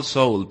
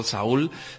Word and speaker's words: Saul: Depende de cómo queramos pronunciar. Yo siempre digo Better Call Soul, pero vamos, si alguien dice Better Call Saul, seguro que no nos van a excomulgar desde la Enciclopedia Británica ni desde la BBC.